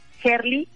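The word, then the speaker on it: Herli: Gerli con H, H-E-R-L-I, Gerli, y tiene dos letras después de Gerli.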